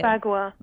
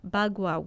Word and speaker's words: bagua: You know, you talk about how to use and place the, and I hope I'm going to say it right, the bug, how do you say it? – Bagua. – Bagua.